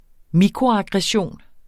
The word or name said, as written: mikroaggression